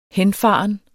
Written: henfaren

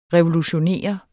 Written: revolutionere

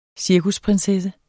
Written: cirkusprinsesse

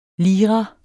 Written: lira

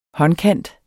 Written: håndkant

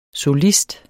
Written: solist

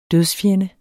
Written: dødsfjende

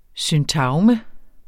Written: syntagme

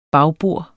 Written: bagbords

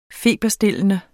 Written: feberstillende

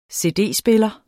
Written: cd-spiller

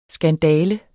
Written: skandale

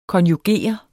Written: KONJUGERER

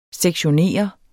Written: sektionere

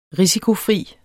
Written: risikofri

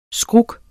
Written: skruk

